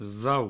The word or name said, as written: vau